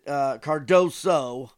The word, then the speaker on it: Cardoso: you know, Johnny Cardosi, I think, is a player that Cardoso. I'm sorry, I'm pronouncing it wrong.